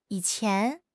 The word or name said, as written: yi3 qian2